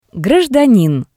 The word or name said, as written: Grajdanin